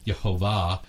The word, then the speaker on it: Yahweh: Now he's going to be known as Yahweh, the covenant keeper. Jehovah is another pronunciation of Yahweh, or sometimes if you look at the Hebrew vowel pointing, it's Yehovah, that kind of thing.